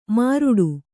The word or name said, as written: ಮಾರುಡು